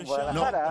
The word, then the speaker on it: Guadalajara: Bueno, Guadalajara lo pronuncia bien, Guadalajara.